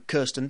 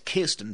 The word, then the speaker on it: Kirsten: It's like I used to pronounce the female star of Spider-Man, Kirsten Dunst. And it's not, it's Kirsten Dunst. – It is, isn't it? It's Kia.